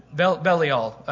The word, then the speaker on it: Belial: Yeah, Belial. Yeah, so the sons of Belial. That's the right way to say it.